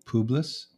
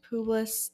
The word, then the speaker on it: Publius: There is an estate nearby that belonged to, how do you say that? – Publius? – Publius, the chief official of the island.